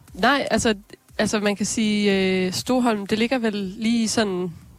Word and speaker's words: Stoholm: Stoholm, tror jeg, de udtaler det. Stoholm.